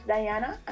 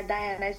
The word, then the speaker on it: Dianna: So on my Instagram, I am Empress Dianna, and my Dianna is spelled with two N's. That is Empress Dianna, D-I-A-N-N-A.